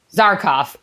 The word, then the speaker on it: Zarkov: The guard kind of shoves Flash, then he shoves Dale, and then it looks like Dale's gonna shove uh, Karkov. Uh, Karkov? Oh my gosh, Zarkov. Zarkov. – Why am I saying that wrong? I was like, that's not right.